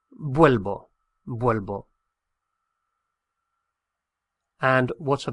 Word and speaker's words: vuelvo: It should be volvo, V-O-L-V-O, volvo. But there's a lot of pressure on that syllable with the O. Vol. And it gets stressed out. And it stresses out to the extent that it turns into UE. So I return is vuelvo, vuelvo, V-U-E-L-V-O, vuelvo.